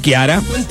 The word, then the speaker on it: Chiara: Paulo de Río Tercero, está bien pronunciado el nombre en italiano. La CH se pronuncia como la Q. Claro, claro, Chiara. Chiara, sí, señor.